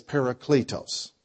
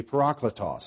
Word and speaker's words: parakletos: The word here that Christ used for helper is the Greek word that's commonly pronounced as parakletos. Correctly pronounced would be parakletos.